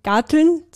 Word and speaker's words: Garteln: Also weil wir vorher im Vorgespräch kurz darauf gekommen sind, genau, persönlich ist Garteln, sagt man das so in Österreich? Habe ich das jetzt gut ausgesprochen? – Ja, genau. Hast du gut.